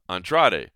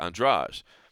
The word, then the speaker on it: Andrade: And I get tongue-twisted on it because – like we say Jessica Andrade, right? Now, before we knew that that was the pronunciation, you probably would have said Andrade. But now we know there's that Rio pronunciation, and so it's Jessica Andrade.